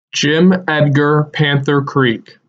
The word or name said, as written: Jim Edgar Panther Creek